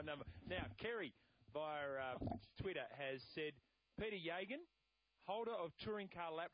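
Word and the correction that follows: Geoghegan: Peter Gagan. Gagan? – Yeah, I didn't know you were talking about him for a sec. Yeah. – Peter Gagan.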